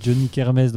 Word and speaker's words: Johnny K'Hermès: Et parce que le nom, c'est quand même un peu un nom à tiroir, parce que c'est Johnny Kermes, donc tu as déjà Johnny Hallyday, il y a la, kermesse, mais ça ne s'écrit pas comme une kermesse. – Non, ça s'écrit K apostrophe et Hermès comme la marque de luxe.